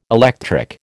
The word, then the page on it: electric: American English